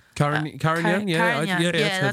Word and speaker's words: Carignan: And it's called Baron de Bossac Carignan. How do you say that? – I don't know that. – Carignan, yeah, that's it.